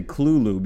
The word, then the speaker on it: Cthulhu: I actually, the first place I heard, and I'm going to say Cthulhu because I just talked to a guy who told me that's the proper pronunciation.